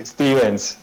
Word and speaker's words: Stevens: Steven, ¿lo dije bien? – Sí, Stevens. – Stevens, con S.